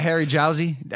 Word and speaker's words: Jowsey: Y'all can follow him on Instagram at Harry Jowsey.